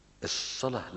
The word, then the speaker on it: الصلاة: الصلاة لما تقراها في المصحف تلاقيها صاد لام واو ت مربوطة، مش كده؟ مش لام ألف، صاد لام واو ت مربوطة، وتلاقي فوق الواو مادة كده عشان يقول لك انطقها ألف، لأن الصلاة أصلها صلوة بالواو، دي أصلها أصل الكلمة صلوة، والصلوة واحدة الصلة.